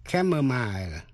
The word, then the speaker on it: chamomile: Sau đây có những chữ mà âm CH đọc là K, như trong ba chữ chaos, C-H-A-O-S. Chaos là sự hỗn loạn. Chamomile hay là chamomile là thứ hoa cúc La Mã để trộn với trà, C-H-A-M-O-M-I-L-E, chamomile hay là chamomile.